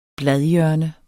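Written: bladhjørne

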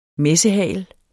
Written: messehagel

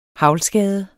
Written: haglskade

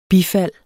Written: bifald

